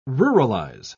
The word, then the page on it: ruralize: British English